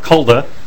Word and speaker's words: Kulda: My name is Mark Kulda. That's spelled K-U-L-D-A.